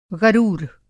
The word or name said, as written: ग़रूर